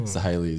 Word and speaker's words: Sahely: So Sahely is the correct pronunciation, but well done.